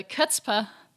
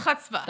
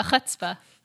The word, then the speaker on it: chutzpah: We have this scene where Varric finally gets the chutzpah to... – You didn't even say that right. – How do you say it? – The chutzpah. – The chutzpah. Guys, I'm learning. Thanks for teaching me.